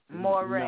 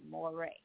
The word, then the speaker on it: Morray: Moray. – No, have you heard of Moray? – Spell it, is it M-O-R-E or? – M-O-R-R-A-Y, Moray.